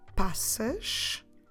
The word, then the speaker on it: passas: Raisins, we say passas. Passas. Como se escreve passas? P-A-S-S-A-S.